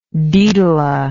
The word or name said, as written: daedala